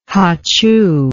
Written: hachoo

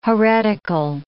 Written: heretical

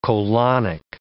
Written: colonic

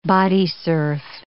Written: bodysurf

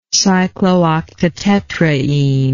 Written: cyclooctatetraene

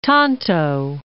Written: Tonto National Monument

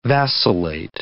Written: vacillate

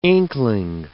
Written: inkling

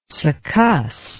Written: succuss

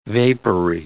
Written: vapory

